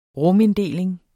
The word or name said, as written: ruminddeling